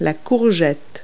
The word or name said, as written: zucchini